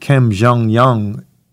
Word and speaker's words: Kim Jong Un: Kim Jong-un and I may have said his name wrong, one in charge of North Korea.